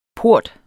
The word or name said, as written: port